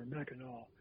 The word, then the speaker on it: Mackinac: which is Mackinac or Mackinac, as the locals say.